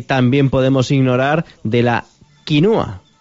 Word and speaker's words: quinua: que la forma correcta de decirlo sería quinua, con U, no con O.